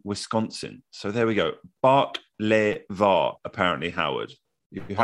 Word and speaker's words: baklava: Dino said baklava is pronounced bark-le-va, accents on first and third syllables. Think the Beatles, Drive My Car.